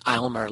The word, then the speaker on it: Aylmer: He was at a place called, I believe it's pronounced Aylmer Lake, A-Y-L-M-E-R.